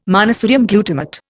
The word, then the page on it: monosodium glutamate: Indian